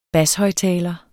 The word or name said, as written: bashøjttaler